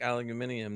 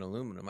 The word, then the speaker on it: aluminium: I mean, aluminium does sound better than aluminum. I mean, it does have a nice flow to it. – Aluminium. – You know, it's a hell of a lot more syllables.